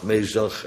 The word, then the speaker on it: Mesach: Daniël 3, waar letterlijk zo'n beeld staat in Babel en waar Sadrach, Mesach en Abednego, ja, anders komt het met de muziek niet overeen, dus zo moet je het uitspreken, Sadrach, Mesach, Abednego.